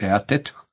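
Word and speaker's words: eateth: Sljedeća priča tiče se specifičnosti engleskog prijevoda drugog stiha, s obzirom da je za riječ blaguje upotrebljena riječ eatet, koja se izgovara poput imena Edit.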